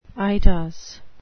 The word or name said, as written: eidos